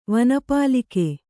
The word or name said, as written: ವನಪಾಲಿಕೆ